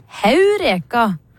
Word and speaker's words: Heureka: I gammelgresk har de et aspirasjonstegn over E som viser at det skal uttales Heureka med H.